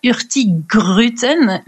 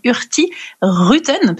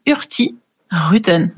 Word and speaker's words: Hurtigruten: On ne prononce pas Hurtigruten, mais Hurtigruten, parce qu'en fait, étymologiquement, ça vient de Hurti, qui veut dire rapide, mais on ne prononce pas le petit G, et Ruten, qui veut dire route, donc du coup on prononce Hurtigruten.